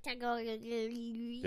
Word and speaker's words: Tagovailoa: Who's better, Tua Tagovailoa or Joe Burrow? – Burrow. Go Tagga. That's how you say his name. Yeah, you have to slow it down to hear it. – Tua Tagovailoa.